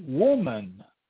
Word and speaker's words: woman: But if I spell it W-O-M-B-A-N, womb ban, you see that's not the correct pronunciation based on what W-O-M-B says. W-O-M-B says womb. Okay, so then W-O-M-B-A-N would be woman. Would be woman. So what is the essence of woman? The womb. But what's the? That's the secret. Wherever there's a silent letter, there's a secret.